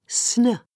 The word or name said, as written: sn